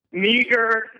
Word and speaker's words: Meagher: Meagher. I mean, it's spelled M-E-A-G-H-E-R.